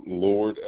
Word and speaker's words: Lord: John Lord, L-O-R-D.